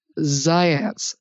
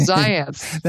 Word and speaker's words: Zajonc: If I say his name, I'll have to spell it for everybody, because I believe it was pronounced Zients, but I think he was Yugoslavian or something. And it's actually spelled Zajonc, believe it. It looks like Zajonc, I think, but it's pronounced Zients.